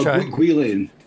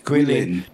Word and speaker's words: Guilin: I'm not sure how to pronounce this. Is it Guilin? – Oh, Guilin. – Guilin.